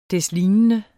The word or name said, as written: deslignende